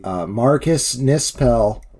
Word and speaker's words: Marcus Nispel: Marcus Nispel got is that how you say it? – It is.